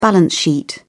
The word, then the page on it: balance sheet: British English